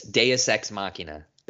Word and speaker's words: Deus Ex Machina: This is a textbook definition of Deus Ex Machina. How do you say it? – Deus Ex Machina. – Thank you.